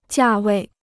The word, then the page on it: 价位: jià wèi